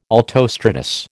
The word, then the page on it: altostratus: American English